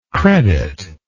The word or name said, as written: creditless